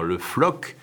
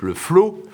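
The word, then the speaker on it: Le Floc'h: le père Le Floc, F-L-O-C apostrophe H, qu'on prononce en principe le flot,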